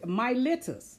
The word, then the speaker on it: Miletus: Okay, Miletus. That's how that's pronounced.